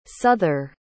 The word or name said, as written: suther